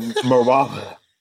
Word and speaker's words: Marawa: So Marawa.